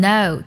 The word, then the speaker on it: know: 第一组呢，就是 k n 开头，k 不发音，像 know 知道，还有 knife 刀子都是这样的。